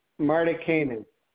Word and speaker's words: Martikainen: Robert Martikainen.